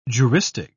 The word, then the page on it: juristic: British English